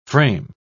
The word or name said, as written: frame